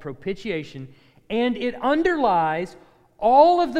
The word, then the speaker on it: propitiation: In the Old Testament, the idea of propitiation, I know you're going to ask, how do you spell that? P-R-O-P-I-T, so it's pro, pit, I, A, tion. Propitiation.